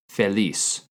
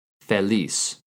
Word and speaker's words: feliz: It's feliz. Feliz. F-E-L-I-Z. In Spain, you also might hear it pronounced feliz. Feliz.